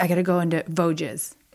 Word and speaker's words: Vosges: It's Vosges.